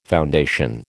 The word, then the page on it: foundation: American English